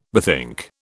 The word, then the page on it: bethink: British English